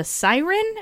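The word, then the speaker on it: Syren: And this time, she also became captain of the Siren.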